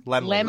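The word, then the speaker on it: Laemmle: How do you say their last name? – Lemley. – Lemley. – Limley's. I guess the A is silent.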